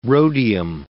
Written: rhodium

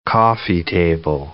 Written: coffee-table

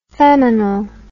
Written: feminal

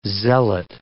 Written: zealot

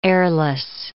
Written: heirless

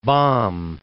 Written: Baum

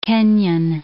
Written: Kenyan

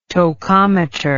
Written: tocometer

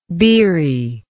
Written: beeriness

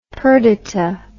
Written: Perdita

